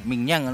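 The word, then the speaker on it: Maignan: Magnan, ¿cómo es Magnan? – Miñán.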